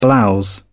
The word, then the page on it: blouse: British English